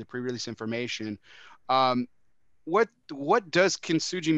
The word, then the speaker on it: Kintsugi: Okay, so first, and I'm going to correct you just so you know, Kintsugi. So Kintsugi. Just with the hard G.